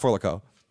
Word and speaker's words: Four Loko: It's pronounced For Loco.